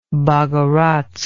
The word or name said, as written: bogorodsk